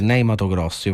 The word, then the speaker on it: Ney Matogrosso: Che è Ney Matogrosso. Io faccio la pronuncia così italiana. – Hai detto benissimo.